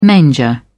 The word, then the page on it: manger: British English